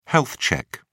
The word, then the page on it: health check: British English